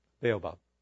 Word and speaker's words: baobab: The other thing that's interesting about his life, which I guess this is the Babo, you may be able to pronounce it better than I can, but Babo, Baobab.